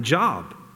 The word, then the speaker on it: Job: That'll be really helpful because it's about my job, right? J-O-B, it's spelled J-O-B, but it's not job, it's Job, and it's not about work.